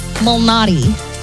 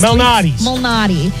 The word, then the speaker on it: Malnati: In golf, Peter Illuminati? What? – That's not it. And I was saying it right. It's just now I'm not saying it right. Peter Malnati. – Leads, like the pizza place.